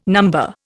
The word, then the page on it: number: Indian